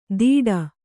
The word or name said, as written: ದಿಡ